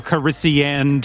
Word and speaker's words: Calrissian: Lando Calrissio, just like that. – You kind of screwed up the pronunciation, but you got the answer. Lando Calrissian, just like that is.